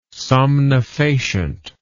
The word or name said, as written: somnifacient